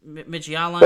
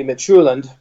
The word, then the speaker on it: Midtjylland: Is that how you say it? Midtjylland? – Well, I don't speak Danish, but my gut feeling would be Midtjylland. – Midtjylland, okay.